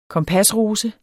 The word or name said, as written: KOMPASROSE